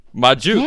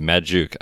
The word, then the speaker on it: Magdziuk: Magdziuk. – Yeah. – For some reason, I froze and I wanted to say the D, Magdziuk.